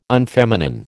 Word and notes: unfeminine: American English

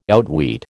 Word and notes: outwit: American English

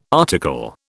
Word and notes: article: British English

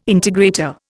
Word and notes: integrator: Indian

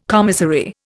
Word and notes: commissary: Indian

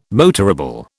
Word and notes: motorable: British English

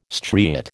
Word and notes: striate: American English

